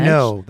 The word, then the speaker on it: Renault: Renault. That's Renault. A Renault. The car. – That's called the Le Car. – Le Car. – Le Car. – So they took a Renault, a French-made car.